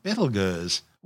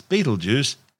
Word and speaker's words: Betelgeuse: Currently known as Betelgeuse, and commonly referred to by the public as Betelgeuse, don't say it three times, the names are both tortured mispronunciations of the original Arabic name Ibtal Yauza, meaning the hand of the big man, the big man being Orion the hunter.